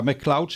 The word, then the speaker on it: McLeod: Maar wat ook zo bijzonder was, dat Cynthia McLeod was daar, McLeod zeg ik het goed, Cynthia? – Ja.